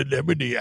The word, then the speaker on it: anemone: It's called an anemone, actually.